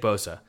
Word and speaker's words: Bosa: It rhymes with Sosa. – It does. – Yeah. It does. Yeah. Yeah. – It does. It's Nick Bosa.